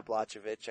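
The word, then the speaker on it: Błachowicz: I never Blachowicz. – Get this. Yeah, Blachowicz.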